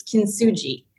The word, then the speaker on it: Kintsugi: And it's Kintsugi. That's what the internet told me repeatedly. So that's how I said it.